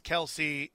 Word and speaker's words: Kelce: Whereas Travis Kelce told us that his name is being pronounced incorrectly, that it's Kelce, and everyone's like, that's great, Travis Kelce.